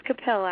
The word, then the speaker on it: Capil: I do believe I butchered your name earlier, but it is Capil, correct? – It's Capil, actually.